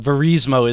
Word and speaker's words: Verismo: I did look up. Verismo is the right pronunciation.